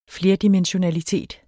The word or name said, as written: flerdimensionalitet